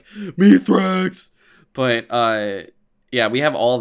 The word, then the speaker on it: Mithrax: How do you pronounce Mithrax's name again? This is Mithrax. – The fallen, or the Elixni, call him Mithrax. Like with an S. – Okay, so with the TH is the wrong one.